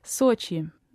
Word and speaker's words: Sochi: Sochi. Mm. The vowel is somewhere in between the British English O and O, so that's why it tends to be anglicized as Sochi.